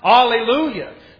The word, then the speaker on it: alleluia: Well, this is how the Bible tells you. It says it's pronounced alleluia.